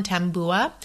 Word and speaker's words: tabua: It's spelled T-A-B-U-A, but the pronunciation I found includes like an M sound, tambua.